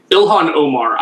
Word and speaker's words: Ilhan Omar: Ilhan Omar.